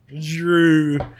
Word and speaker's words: Jrue: Drew. How do you spell that? – J-R-U-E.